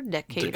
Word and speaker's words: Decatur: I think that's how you say it. Decatur? Decatur. – Decatur, typically. – Georgia.